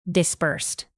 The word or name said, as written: dispersed